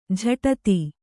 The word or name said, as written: ಜಡಿತ